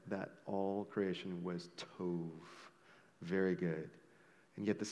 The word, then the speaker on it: tov: And Jake, in his message last week, he had mentioned that the Hebrew word tov, which was very good, tov, you've pronounced it completely right, tov, right?